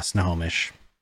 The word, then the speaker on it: Snohomish: Snohomish. – That's actually right. Yeah, Snohomish.